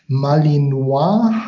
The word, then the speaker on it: Malinois: Malinois heißt dieser, wenn er denn französisch ausgesprochen wird.